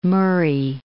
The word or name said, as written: Moray